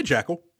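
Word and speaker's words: Jaeckel: How would you say that? – I'd say Jackal.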